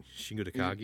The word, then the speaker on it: Shingo Takagi: You got Shingo Takagi. Why did I say it like an idiot? Shingo Takagi. – You said it like a white guy.